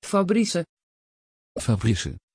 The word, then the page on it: fabrice: Dutch